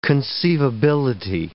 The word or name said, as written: conceivability